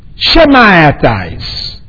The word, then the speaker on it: Shammaites: The second I put is the Shemaites, is spelled as Shammaites.